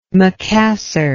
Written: macassar

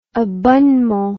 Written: abonnement

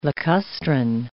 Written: lacustrine

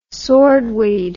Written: swordweed

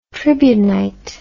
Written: tribunite

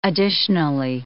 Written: additionally

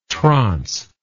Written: transe

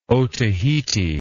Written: otaheite

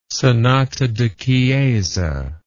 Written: sonata da chiesa

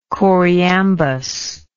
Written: choriambus

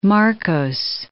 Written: Marcos

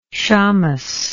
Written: shames

